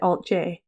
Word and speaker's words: Alt-J: This was kind of our band name, but we chose to pronounce it Alt-J, because we knew we were being played on the radio.